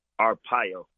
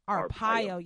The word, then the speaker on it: Arpaio: Am I saying it right? How do you say his last name? – Yes. Arpaio. – Arpaio. Yes. Joseph, thank you, Arpaio, for that.